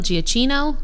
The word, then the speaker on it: Giacchino: So if we're talking about compositions, most of the time, and I will always butcher his name, so I'm sorry, Michael Giacchino? – Yep. – Yay, I did it right that time.